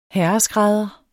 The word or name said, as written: herreskrædder